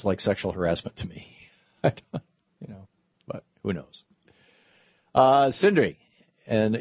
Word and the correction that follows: Sindra: It's Sindra, but yes, Sindra.